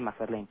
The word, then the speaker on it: McFarlane: Pero McFarlane, ¿se dice así McFarlane? – Sí, McFarlane.